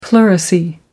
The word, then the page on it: pleurisy: American English